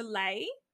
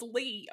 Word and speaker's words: Leigh: And remember when everyone used to mispronounce her first name and they'd call her Lay? – No. – I remember. It's spelled Lee. – Like, yeah, exactly. I was like, it's Lee.